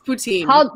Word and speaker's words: poutine: It's poutine. – How do you spell it? Put with P? – P-O-U? Mm-hmm. T-I-N-E.